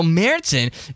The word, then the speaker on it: Märzen: But and for those who don't know, the A is the, with the double dots is the sound of A, long A. So, and then the Z has got the TS sound. So that's why I call it Marzen.